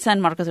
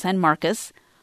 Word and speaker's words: San Marcos: San Marcos wasn't San Marcos, it was San Marcos.